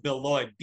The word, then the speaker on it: Beloit: I want to go with Beloit, B-E-L-O-I-T.